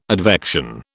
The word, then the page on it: advection: British English